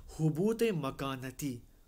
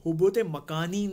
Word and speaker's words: حبوط مکانتی: حبوط مکانتی. کیا کہتے ہیں، حبوط مکانی نہیں، ہاں، حبوط مکانتی. ہم کہتے ہیں نا زمان و مکان، اسی سپیلنگ سے مکان ہے، حبوط مکانتی ہے یہ، یعنی اپنے مقام اور منزلت سے نیچے آ جانا، ٹھیک ہے.